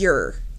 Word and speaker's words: Ure: So it rhymes with pure? James Ure.